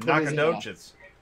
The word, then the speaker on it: Nacogdoches: Nacogdoches. That's not Joe Docus or whatever.